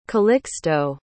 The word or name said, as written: calixto